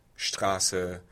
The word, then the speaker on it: Straße: And there's a German rule of S before T or P being pronounced as sh. So Sport, Straße, which is interesting that the consonant context is influencing the pronunciation rather than the vowel context.